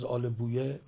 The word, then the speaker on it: آل بویه: آل بویه و یا آل بوی که هر دو درسته، یکیش تلفظ فارسی و یکی عربی است، سی بویه، سی بوی، قولویه، قولوی، با ابن بابویه، بابوی، اینا هر دو درسته.